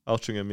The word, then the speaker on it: Altrincham: Altrincham. – Did I say that properly? That's how it's spelled. How'd you fucking say it? Altrincham. – Altrincham, yeah. – Well, where's the fucking G come from?